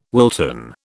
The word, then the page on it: Wilton: British English